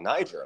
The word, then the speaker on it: Niger: A lot of people call it Niger. – Yeah. – Niger is like the French pronunciation, but they're a French-speaking country, so it makes sense, right? – Former colony.